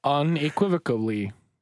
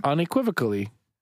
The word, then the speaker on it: unequivocally: Unequivocally. – Pronounce that one more time. – Unequivocally. – Yeah, you added a syllable the first time. – What'd I say? – Unequivocally. You put a B in there.